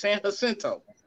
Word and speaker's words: San Jacinto: People call it San Jacinto. It's San Jacinto. You got to pronounce it. Yes, and I thought the J wasn't pronounced right. – It's pronounced as an H.